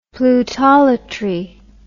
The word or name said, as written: plutolatry